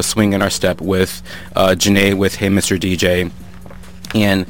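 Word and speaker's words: Zhané: Pronounced Janae, that's Z-H-A-N-E, with Hey Mr. DJ.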